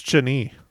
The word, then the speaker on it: Chani: China? No, I don't know. – No, it is not China. – I don't know. How do I say that? – I think it's Chani.